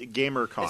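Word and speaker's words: Gam3rCon: But it's called GamerCon, but the E in gamer is a 3. So it's kind of like elite speak or whatever. So Game 3 or con, but it's pronounced GamerCon. It's pronounced so GamerCon, it's phonetically the same.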